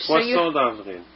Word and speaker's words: Poisson d'Avril: Is that how you say it? Say it for me. – No. – Please? – Say it in French. – You're French. – Poisson d'Avril. – Poisson d'Avril. Is that right? – Just never mind.